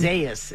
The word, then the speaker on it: Isaias: Isaias is what the pronouncer says. – It seems like it's Isaias, but I remember it being said a different way.